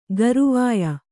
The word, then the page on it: ಗರವೊಯ್: Kannada